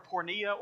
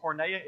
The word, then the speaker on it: porneia: You're reading a word that in the original Greek language, you can pronounce it a couple of different ways. It's the word porneia or porneia.